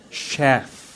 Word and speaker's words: Sheth: when you say that word, you gotta pronounce it with Sheth. Practice. Sheth. Yeah. Okay. Don't slip the H, okay?